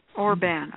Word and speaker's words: Orban: O-R-B as in boy, A-N. – Orban. Okay, got it. So Orban.